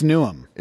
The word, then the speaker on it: Newham: It's spelled Newham, but it's Newham. – It's Newham. You got that? – It's Newham.